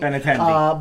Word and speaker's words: Benintendi: however you say that, Italian last name, Bentiendi, whatever. If you're a Royals fan, you're probably checking in, you're like, Jesus Christ, this guy can't pronounce this guy's name. – Benintendi.